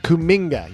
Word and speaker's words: Kuminga: Kuminga, yes, I think that's right.